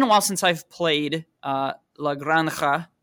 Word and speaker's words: La Granja: So you pronounce La Granja, La Granja, yeah. – Yeah, the right pronunciation of the game is La Granja. – La Granja. – La Granja. But La Granja. To a German tongue, this is very complicated. And in order to not break it, I'm always using the German J, La Granja.